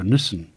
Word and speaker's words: Nissan: British English and American English speakers frequently disagree over Nissan, with those in the United Kingdom preferring to say the more literal Nissan.